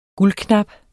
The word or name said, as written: guldknap